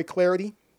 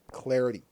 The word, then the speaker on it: Clardy: Speaking of Twitter, you can follow me at Troy Clarity. Last name is spelled C-L-A-R-D-Y at Troy Clarity.